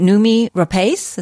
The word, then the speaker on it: Noomi Rapace: Noomi Rapace, is that how you say it? – Yes, exactly.